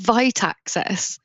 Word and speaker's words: Vita Access: So it's Vita Access. – Oh. – Almost like a V-Y-T-E.